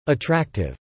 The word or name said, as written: attractive